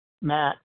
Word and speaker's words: Matt: My first name, Matt, rhymes with fat, which was not fun for me as a kid.